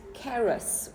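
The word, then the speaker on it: Charis (χάρις): There we go, with the Greek accent. Okay. It's charis. – Charis, charismatic. – See. Okay. So the Greek word for favor is charis,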